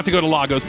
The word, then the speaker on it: Lagos: It's pronounced Lagos, says the Twitter.